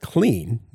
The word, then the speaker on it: Cleen: A free iPhone app called Clean, that's spelled C-L-E-E-N, makes sorting through and deleting your images in full size much faster.